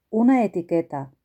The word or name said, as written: una etiqueta